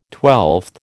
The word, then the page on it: twelfth: American English